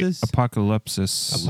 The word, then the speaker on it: apokalypsis: Did I say that right? – Probably not. – Yeah. – Apokalypsis? – I don't know about loop. I would say apokalypsis. – Apokalypsis? All right. The Greek word for revelation, apokalypsis. Apocalypse? – Yeah. – Sis. – There you go.